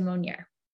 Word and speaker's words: Monnier: Yeah, it's my name, Amanda Monnier.